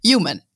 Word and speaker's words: Human: Ich hoffe, es habe es richtig ausgesprochen. – Human. – It's English, get it?